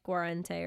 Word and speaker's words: Guarente: It's not the first one. There wasn't an N in the middle. – Fair enough. Guarente? – Yeah, I would say like Guarente or something like that.